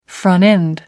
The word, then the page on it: front end: American English